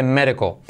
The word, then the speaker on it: medical: Hey, number 60, médico, medical, lo mismo, muy parecido en la escritura, pero fíjate cómo se dice medical. His medical condition is pretty good. – His medical condition. His medical condition. – His medical. D como R suave, I como E, letra clave L, medical.